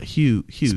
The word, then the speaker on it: Hughes: It's definitely Hughes. – Hughes. – Spell it. – H-U-G-H-E-S. Hughes. – Hughes. Like John Hughes.